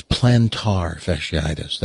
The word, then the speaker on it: plantar fasciitis: Most people go in there for If you really say it correctly, it's plantar fasciitis. That's, you know, the correct way to say it.